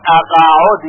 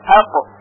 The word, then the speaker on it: اقعد: توجه داشته باشید که در کلمه اقعد قاف ساکن هست و بعضی ها شنیده شده که میگوین اقعد، یعنی با قطعه که این غلط هست، بلکه ساکن باید تلفظ بشه اقعد.